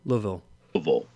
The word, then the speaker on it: Louisville: I guess we should pronounce it properly. Go ahead. – Wax Fang? – No. – Louisville. – Louisville. Yeah. Louisville.